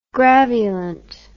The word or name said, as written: graveolent